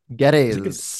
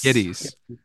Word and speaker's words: Gittes: Uh, is that you say his name? – Geddes. Geddes.